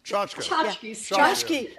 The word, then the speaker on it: tchotchke: Maggie Haberman, great reporter for the New York Times, has talked about how do you say that word? Tchotchke? – Tchotchke defense.